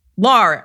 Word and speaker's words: Lar: Lar, as as he pointed out, rhymes with bar.